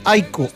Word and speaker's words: Aiko: Oiga, un buen amigo que está en sintonía, Marcelo, que vive en Suecia, dijo: no tengan inconvenientes, no se compliquen, díganle Aiko al equipo, pronúncielo como... ¿Cómo? El Aiko. – El Aiko. – Aiko, sí, nos dijo Aiko.